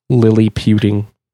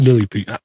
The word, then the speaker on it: LilyPutin: LilyPutin. – LilyPutin. There you go. – Yep. – LilyPutin. I just think you got to say it like you're saying something's really cute. Oh, well, LilyPutin. – Yeah. You know. Think of it as Lilliput, like from Gulliver's Travels, but then computing. So LilyPutin.